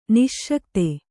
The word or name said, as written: ನಸಿಗುತ್ತ